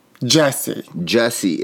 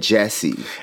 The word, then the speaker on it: Jussie: Wait, how do you say his name? – Jussie. It's not, it's like with a U instead because it's like different. It's like eclectic. – Well, how do you pronounce it then? – Jussie. – No, you said Jesse. – It's Jussie. – Jussie. Jussie Smollett. – Jussie Smollett. – Exactly. – So it's Jussie. It's not Jussie.